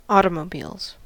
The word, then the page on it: automobiles: American English